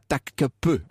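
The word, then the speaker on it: Topkapı: Alors, il paraît que ça, il faut prononcer ça Takkapi. Alors, admettons, mais moi, je veux dire Topkapi, c'est plus facile, hein, pardon.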